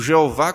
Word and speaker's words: Iavé: Iavé, as quatro letras que, que podem também ser pronunciadas como Jeová, com total reverência. Na verdade, a pronúncia desse nome foi perdida, dessas quatro letras do hebraico, foi acrescentada as vogais de Adonai, e aí vem essa pronúncia Jeová.